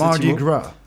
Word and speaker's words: Mardi Gras: Ou Mardi Gras, mais ils le disent exactement comme tu l'as prononcé. – Mardi Gras. – C'est exactement ça, Mardi Gras.